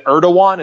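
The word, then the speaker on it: Erdoğan: And one of the supporters, is it Erdogan? Is that how it's pronounced? – Yeah, I'm pretty sure it's spelled Erdogan. I'm pretty sure the G is pronounced with a W.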